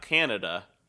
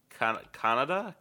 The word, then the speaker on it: Kannada: It's not Canada. I don't believe that's pronounced Canada. Kannada. KANNADA. So it's box.